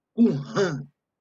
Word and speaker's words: Qumran: Segundo, apareceu diversos grupos, conhecidos como os apocalípticos, e tudo se concentrava nessa comunidade Qumran. Eu acho que a pronúncia correta é essa, Qumran.